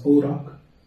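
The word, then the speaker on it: Oroch: Still on the topic of Bakis, do you remember Reynolds, I think it It's pronounced Orok.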